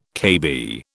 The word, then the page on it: KB: British English